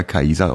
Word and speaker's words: Caesar: Äh, ich glaube, dass Kaiser doch eins drüber ist, weil das Wort Kaiser kommt ja vom lateinischen Caesar, der Kaiser ausgesprochen wird, korrekterweise eigentlich. Wie? Und Bitte, wird der ausgesprochen? Kaiser. Also nicht mit Kaiser? – C. Ja, also nicht mit C, sondern mit K.